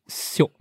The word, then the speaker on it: Seok: S-E-O-K, how to pronounce in Korean? – Shiok.